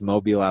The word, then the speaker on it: Mobile: I want to start off here by talking about your hometown, which I believe is Mobile, Alabama. Am I saying it right? – It is, yes.